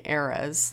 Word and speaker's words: ERAS: But the reason why we missed last week was because it was ERAS week, and unfortunately I'm not mispronouncing ERAS, like the Taylor Swift kind of era.